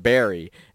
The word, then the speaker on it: Bury: And so I said Bury, and everybody was like, that's not how you pronounce that word. It's pronounced Barry. And I'm like, where's the A in this word?